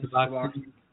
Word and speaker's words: Suboxone: Suboxone.